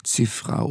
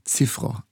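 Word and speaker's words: Cziffra: Die Aussprache des Namens variiert. Georges Schiffre oder Ziffro eigentlich korrekt.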